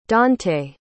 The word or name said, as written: dontae